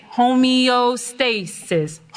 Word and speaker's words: homeostasis: Okay, so if you look at the pronunciation, homeostasis, homeostasis. Okay, remember that.